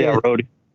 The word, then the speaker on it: Rodian: Is that how you say it? Rodan or Rodian? Or how do you say Rodian. Rodian.